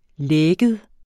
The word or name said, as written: lægget